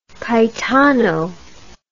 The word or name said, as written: Caetano